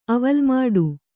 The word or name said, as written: ಅವಲ್ಮಾಡು